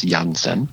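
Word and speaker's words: Janssen: I understand in German it's Jansen, but Father Arnold Jansen was a diocesan priest in Germany in the 1800s whose heart beat for God's people around the world.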